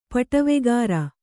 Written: ಪಟವೆಗಾರ

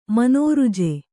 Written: ಮನೋರುಜೆ

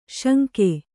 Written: ಶಂಖಿ

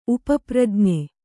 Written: ಉಪಪ್ರಜ್ಞೆ

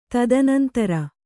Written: ತದನಂತರ

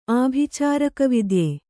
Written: ಆಭಿಚಾರಕವಿದ್ಯೆ

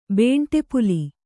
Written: ಬೇಂಟೆಪುಲಿ

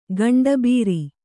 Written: ಗಂಡುಬೀರಿ

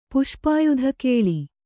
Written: ಪುಷ್ಪಾಯುಧಕೇಳಿ

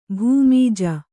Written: ಭೂಮಿಜ

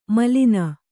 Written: ಮೇಲನ

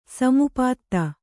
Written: ಸಮಾಪ್ತ

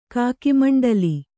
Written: ಕಾಕಿಮಂಡಲಿ

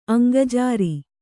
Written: ಅಂಗಜಾರಿ

